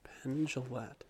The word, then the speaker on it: Jillette: J-I-L-L-E-T-T-E. – J-I-L-L-E-T-T-E. Okay. Penn Jillette. So not Gillette like the shaver. – Nope. That's the G.